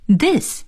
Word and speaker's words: this: 下面再来看看一些其他的词，比如表示是，我们就说 yes y-e-s yes，表示不是呢应该是 no no n-o no，所以呢是是 yes，不是是 no 再来看看 this 这个词 t-h-i-s this this 是指这个这。在这里呢请朋友们注意 TH 的发音，在发 TH 这个音的时候呢一定要把舌尖放在上牙齿和下牙齿的中间。